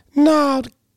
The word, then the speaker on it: gnarled: They're benarled. Do you know what that means? – Benarled? – Gnarled. G-N-A-R-L-E-D. – Oh, gnarled. – Gnarled. – Oh, you don't pronounce the G. – Yes, like gnarly. Gnarled.